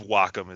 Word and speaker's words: Wacom: People say it different ways, but I believe Wacom is the, some people say Wacom. – Yeah. – But, I believe Wacom is the correct way. – Sock'em and Wacom.